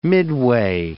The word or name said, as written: midway